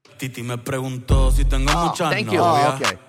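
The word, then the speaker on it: Tití Me Preguntó: So in Ohio, they would say, titty me pregunto. – Right. – But that's not how it's pronounced, right? Because it's Spanish. So it's more like something like titi me pregunto.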